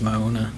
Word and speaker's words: Mauna: It's M-A-U-N-A. – I think it's Mauna. – Mauna. Yeah, that's right. Because you say every vowel in Hawaiian, right?